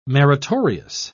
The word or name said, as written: meritorious